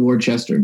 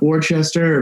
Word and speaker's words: Worcester: You can't pronounce the last R too, right? It's not Worcester; it's Worcester. Like you kind of hit it with like that Boston.